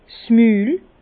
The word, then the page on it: 's Mül: Bas-Rhin; Herrlisheim